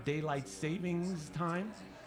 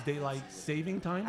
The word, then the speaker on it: daylight saving time: Did that it's not daylight savings time? It's daylight saving time.